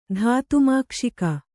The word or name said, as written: ಧಾತುಮಾಕ್ಷಿಕ